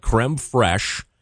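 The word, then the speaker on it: crème fraîche: He says it's called creme fraiche and then empanada. For some reason, I kept saying empanada or something like that.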